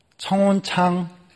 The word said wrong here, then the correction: 청혼장: 아, 발음을 잘해야 되는데 교회는요 청혼장 좀